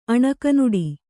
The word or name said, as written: ಅಣಕನುಡಿ